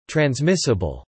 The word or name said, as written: transmissible